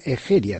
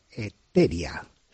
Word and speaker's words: Egeria: Una la tomo de una escritora gallega importantísima, que se llama Egeria, también se pronuncia Eteria.